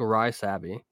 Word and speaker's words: Rysavy: We got Stanislav Svozil and Martin Rysavy. – Rysavy. – It's got a Y at the end of it. It's spelled like R-Y-S. It's like Rysavy.